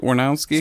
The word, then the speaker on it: Wernowski: Am I saying that right? Wernowski? – Sounds right.